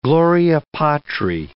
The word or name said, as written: Gloria Patri